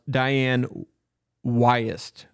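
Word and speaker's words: Dianne Wiest: Her name is pronounced Diane Wyest.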